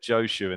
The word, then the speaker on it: Joshu: I've started the podcast very confidently, said Joshu, and then didn't double check with you. That's how you pronounce it. So have I started right? We're in the... – I think so. That's how we pronounce it.